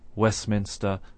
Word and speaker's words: Westminster: If we take the word postman, for example, the normal way of pronouncing postman is with no T sound in the middle. Of course, we can slow down our pronunciation and say postman if we wish, but normally we just say postman. Similarly, we say Westminster and not Westminster.